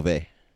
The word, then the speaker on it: Beauvais: Beauvau? – Yeah. – How do you say her last name? – Beauvais.